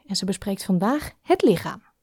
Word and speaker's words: lichaam: Het lichaam. So it's written with CH, but we pronounce it as our favorite letter, the G. Het lichaam.